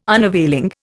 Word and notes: unavailing: Indian

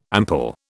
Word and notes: ample: British English

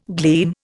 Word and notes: gleam: Indian

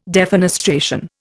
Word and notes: defenestration: Indian

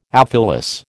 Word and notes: aphyllous: American English

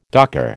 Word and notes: docker: American English

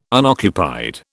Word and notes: unoccupied: British English

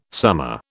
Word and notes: summa: British English

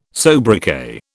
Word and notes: sobriquet: British English

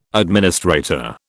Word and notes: administrator: British English

